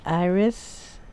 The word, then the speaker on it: Iris: It's Iris Donita Bowen, and it's spelled I-R-I-S. Donita is D-O-N-I-T-A, and my last name is Bowen, B-O-W-E-N.